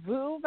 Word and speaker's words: Vous: So it's called VU Vitamins. So V-O-U-S. VU means you in French. And that's why we named the company VU Vitamin, because we are about personalized vitamins for each person.